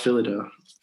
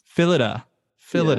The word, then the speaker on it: Phyllida: Did I pronounce that right? – I think it's pronounced Phyllida. – Phyllida. Yeah.